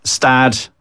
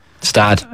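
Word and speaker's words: Gstaad: We have Stad. You never pronounce the G, and I've learned this the hard way. – Stad.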